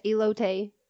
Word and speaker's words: elote: The weirdest one is, I always forget how to say it, elote? – Yes, elote.